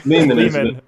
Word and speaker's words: Lehman: However you'd like to say it, but Lehman.